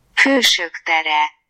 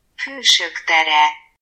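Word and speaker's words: Hősök tere: So we took the subway a few stops over, and our first stop was Heroes Square, or as they say in Hungarian, Pushuktere. Pushuktere. – So now you know how to say Heroes Square.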